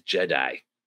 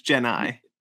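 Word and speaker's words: Gennai: Fun little fact, or fun little side note, when I was a kid, I always I always heard it pronounced as Jedi. – Jedi? No, it's Genai. – Yeah, I didn't hear the N. It sounded like a D to me as a kid.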